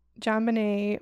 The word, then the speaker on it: JonBenét: Yeah, like so for JonBenét Ramsey, am I saying that right? Yeah. John Yeah. Apparently JonBenét,